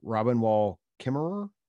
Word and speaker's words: Robin Wall Kimmerer: Is it Robin Wall Kimmerer? – That's correct.